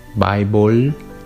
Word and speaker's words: Bible: Para poder lograr hacer este sonido, simplemente tienes que mover ah, tu lengua hacia atrás o hacia arriba un poco, en vez de ah, pronunciar una O con una L, Bible, simplemente mueves tu lengua hacia atrás, Bible, Bible.